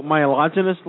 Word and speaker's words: myelogenous: Acute myelogenous leukemia, is that correct? – Yep, you got it.